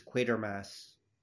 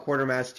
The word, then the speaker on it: Quatermass: Uh, by the way, it's Quatermass. Quatermass. – They okay. pronounce Quatermass. Yeah. – Fair enough. – I used to call it Quartermass too, but apparently I discovered when the Tennant one came out.